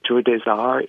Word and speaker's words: Tour des Arts: It's T-O-U-R-D-E-S-A-R-T-S, but we pronounce it Tour des Arts.